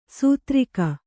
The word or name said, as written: ಸಾತಿರೇಕ